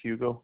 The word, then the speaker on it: Hugo: First name is Hugo, H-U-G-O. Last name is Argueta, A-R-G-U-E-T-A.